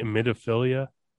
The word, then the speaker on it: emetophilia: Emetophilia.